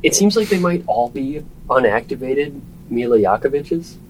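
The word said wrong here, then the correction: Jovovich: Did I pronounce that right? – Yovovich. – Yovovich. I'm sorry.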